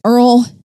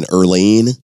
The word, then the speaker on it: Earle: Is that an Earlene? – No, it is not. – Just says Earle. – All right. – E-A-R-L-E, Dennison.